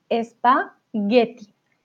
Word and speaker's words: espagueti: Y espagueti, G-U-E, Getty. No ponemos G-E, porque G-E tendría otra pronunciación. estoy pensando gestionar, ¿vale? Si ustedes tienen solamente la G y la E, una pronunciación fuerte. Gestión, gerente, gestionar. Si tienen una U, ya pasa a ser G.